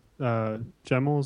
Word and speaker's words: Gemmell: We had Matt Gemmell.